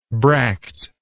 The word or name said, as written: bractless